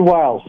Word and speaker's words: Wiles: How do you pronounce her name? Susan Wilkes? – Susie Wiles, sorry.